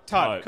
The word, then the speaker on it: taupe: Taupe, cool, yeah, cool. – Taupe rhymes with grope.